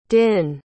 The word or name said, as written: dinh